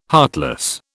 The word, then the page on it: heartless: British English